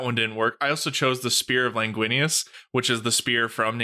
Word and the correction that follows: Longinus: It's Longinus. It's Longinus. – Yeah, Longinus.